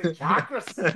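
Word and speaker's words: chakra: So what is it? Chakras. – Chakras. Okay. All right. Like a shock system.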